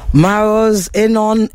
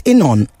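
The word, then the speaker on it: Maoz Inon: Maros Enon, did I pronounce your name right? Is it Enon? Am I pronouncing it right? – It's perfect.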